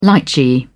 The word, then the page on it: litchi: British English